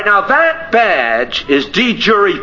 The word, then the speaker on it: de jure: It's pronounced de jure. And it means something that is true in law. De jure. I looked up Tate again in 1980 Oxford. And again, it's de jure. They don't give anything else for it. It's de jure. That's the pronunciation.